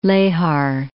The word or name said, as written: Lehar